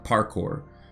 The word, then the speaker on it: parkour: Hay algo que se llama parkour, así se, se pronuncian parkour, pero es P-A-R-K-O-U-R.